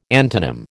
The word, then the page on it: antonym: American English